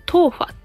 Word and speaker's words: 豆花: そう、そんな夏に、あの、ぴったりの食べ物をね、今日紹介したくて、私が一番好きな食べ物が台湾のデザートのトーファ